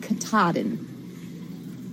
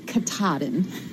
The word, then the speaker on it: Katahdin: Just so that Lamar knows, the correct pronunciation of the mountain in Maine on the Appalachian Trail is Mount Katahdin. You have to say it with a roll on your tongue, Katahdin.